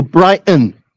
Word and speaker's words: Brighton: And you don't pronounce that T there. You have to go Brighton.